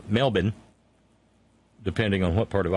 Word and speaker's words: Melbourne: See, John Fox tried to tell me how to say that one night. I kept Mebbin, Mebbin, Mebbin. But it's Melbourne. But there's a reason. It's just like C-A-N-B-E-R-R-A is pronounced Canberra.